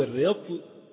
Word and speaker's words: رطل: فكلمة رطل هنا في المثال من المقادير الموزونة، فالرطل أو الرطل، يعني يجوز في كلمة رطل أن تنطق بفتح الراء أو بكسر الراء، اشتريت رطلا زيتا أو رطلا زيتا. والكسر أشهر، الكسر أشهر من الفتح.